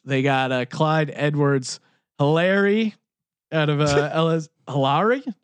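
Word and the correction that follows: Helaire: How do you pronounce Hilaire. – It? Hilaire. – Yeah. It's French, bro.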